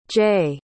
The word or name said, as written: jaye